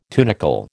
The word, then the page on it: tunicle: American English